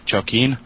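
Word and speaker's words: Jokinee: But you can follow me on Twitter with name Jokin.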